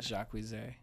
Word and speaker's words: Jacquees: It's spelled... Jacques with two E's and a Z, but his name is Jacquees, but he says it Jacquise.